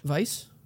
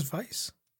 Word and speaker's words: Weisse: Vice? – Fuck is Vice? – It's spelled W-E-I-S-S-E. But it's another monster that you watch her hunt.